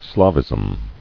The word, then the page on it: Slavism: Slav·ism